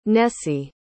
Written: Nessie

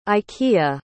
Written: ikea

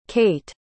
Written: kait

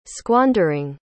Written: squandering

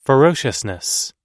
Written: ferociousness

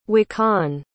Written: wakaun

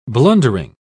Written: blundering

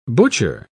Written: butcher

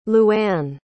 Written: luanne

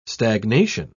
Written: stagnation

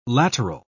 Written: lateral

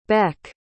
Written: bec

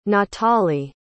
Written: natale

